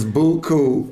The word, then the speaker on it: beaucoup: That's how we say it in New Orleans, Beaucoup.